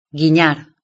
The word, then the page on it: guinar un ojo: Spain Spanish